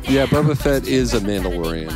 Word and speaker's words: Mandalorian: Wait, Milan? Why can't I say that? Mandalorian. I forgot how to say it for a second.